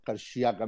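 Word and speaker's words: Karşıyaka: Karxiaga em va dir que es pronuncia, el, el Gerard Castanyer m'ho va dir.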